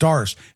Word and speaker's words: Darce: I'm sorry, it's pronounced Darce. It's D. It's Darce. But they refer to it also as the guillotine, which is kind of interesting using that on a Frenchman.